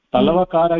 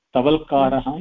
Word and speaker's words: तवल्कार: तलवकार इति लोके जानीमः तलवकारब्राह्मणः इति किन्तु उच्चारणार्थं शास्त्रे छान्दसि तवल्कारः इति एव तस्य नाम तवल्कारब्राह्मणः इति तस्य कर्ता अस्ति जैमिनीमहर्षि इति कृत्वा तस्यापि नाम अस्ति तवल्कारः इति तवल् करोति इति तत्र आम् तेन एव तवल्क ब्राह्मणकृतः अस्ति शाखा तस्य शाखा अस्ति तवल्कारः शाखा इति उच्चारणार्थं तवल्कारः इति छान्दसि वदति लोके तु तलवकार इति एव भवति